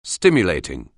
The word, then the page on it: stimulating: anglais